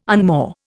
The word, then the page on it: unmoor: Indian